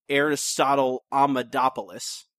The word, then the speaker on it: Aristotle Amadopoulos: and we get a return appearance of Aristotle Amadopoulos. I think I said that right. – Indeed you did.